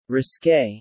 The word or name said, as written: risqué